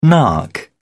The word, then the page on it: nark: British English